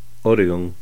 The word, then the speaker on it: Oregon: Idaho, Oregon o Jefferson. Estas tres palabras que se acentúan en la primera sílaba en su pronunciación